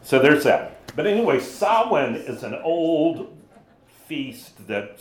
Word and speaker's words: Samhain: So there's a Druidic Celtic feast in Ireland that that is spelled S-A-M-H-A-I-N, which by all rights should be what? – Samhain. – Samhain, exactly. Thank you. But it's not what it's called? – It's Samhain. Just